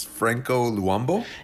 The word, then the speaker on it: Franco Luambo: pronounce i can pronounce at least the first part of this it's uh it's Franco Luambo.